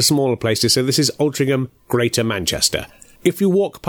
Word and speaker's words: Altrincham: It's an odd one for the pronunciation police, as it's pronounced Altrincham, but spelt A-L-T-R-I-N-C-H-A-M.